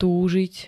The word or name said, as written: túžiť